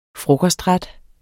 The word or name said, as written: frokostret